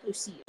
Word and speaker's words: Lucia: It says fans are already in love with Lucia. I think that's how you say her name. L-U-C-I-A, Lucia. – Lucia.